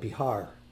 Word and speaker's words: Bihar: And interesting, there's a state, province of India that's called Bihar. And that's actually the contemporary pronunciation of Vihara, which goes back to the pre-Buddhist era.